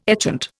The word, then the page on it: etchant: Indian